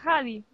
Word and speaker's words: Huddy: Bueno, mis queridas Hads, que sabéis que yo no sabía que se pronunciaba Hadi.